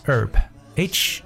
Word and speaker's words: herb: 而我们的传统中药很多都是由植物的某些部分来构成的，所以呢，这种中草药的说法在英文中叫 herb，h e r b。这个 h 其实是一个哑音字母，可以不发音的 herb。